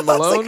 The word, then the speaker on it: Malone: Jenna Maloney. – What? – No, Jenna Maroney? – No, not Maroney. Jenna Malone. Like, how do you say her last name? – Malone. – Jenna Malone, not yeah. Maloney.